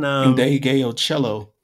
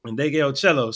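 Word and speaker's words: Ndegeocello: Indegeocello. – Indegeocello.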